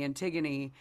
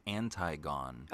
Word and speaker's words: Antigone: And I brought in a monologue from Jean-Henri's Antigone, because I was at that time in my life was obsessed with playing Antigone. And it's pronounced Antigone.